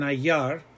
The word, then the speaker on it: hallar: We have encontrar and hallar. Hallar, the H is silent.